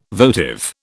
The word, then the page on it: votive: British English